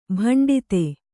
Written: ಬೆಂಡೆತ್ತು

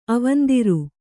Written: ಅವಂದಿರ್

